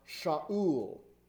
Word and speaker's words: Shaul: Saul's name in Hebrew is pronounced Shaul.